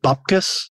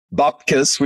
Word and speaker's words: Bupkis: Going to talk about a few things that are also coming along, but Andrew Mercado, my co-host, we're going to start with, do you pronounce it Bupkis? – I think so, yeah. And Bupkis, which is available on Foxtel and Binge, is my surprise package of the year.